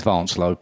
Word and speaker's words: Vincelot: Now, obviously, the English pronunciation of Vincelot is Vincelot, because I asked him how. He says Vincelot. So. Okay, stick with the French Vincelot. And people would know it as Vincelot, but no, insist on calling him Vincelot.